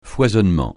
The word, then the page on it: foisonnement: francais